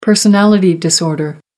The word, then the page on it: personality disorder: American English